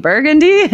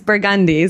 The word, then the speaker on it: Burgundy: Other day in the French Quarter, and I told them that I was going to an art opening on Burgundy, and they were like, "Burgundy? You mean Burgundy?" And I was like, "No, here it's Burgundy."